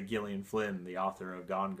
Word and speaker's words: Gillian: So I put a couple of those on my list, and today I listened to The Grown Up by Gillian Flynn, and it's pronounced Gillian on the audiobook. It says Gillian a few times, so I thought it might be Jillian, but it's Gillian.